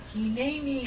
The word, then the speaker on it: hineni: or maybe three words, but it's pronounced as one. – Hineni.